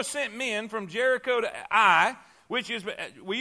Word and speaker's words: Ai: That really is Ai. I know the spelling is, but the right pronunciation is Ai.